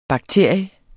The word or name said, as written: bakterie